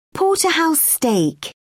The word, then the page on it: porterhouse: American English